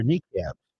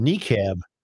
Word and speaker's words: niqab: And some graphite drawings show the bodies of Muslim women wearing hijabs or niqabs, clothing that is usually meant to preserve modesty. Two drawings titled Blasphemy 10 and the Blasphemy 9 show a niqab, N-I-Q-A-B.